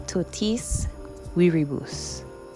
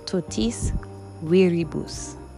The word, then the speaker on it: totis viribus: The word of the day is totis viribus. Totis viribus. Or viribus. It is spelled T-O-T-I-S V-I-R-I-B-U-S.